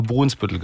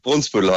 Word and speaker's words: Brunsbüttel: Ich habe wirklich mein Leben lang das eigentlich immer falsch gemacht. Ich habe immer Brunsbüttel gesagt. Immer. – Ja, richtig. Brunsbüttel heißt es. Wenn die Medien auch falsch gesagt. – Brunsbüttel. Ne? Also wir merken genau. Uns das für alle Zeiten. Es heißt Brunsbüttel und nicht Brunsbüttel. So, richtig.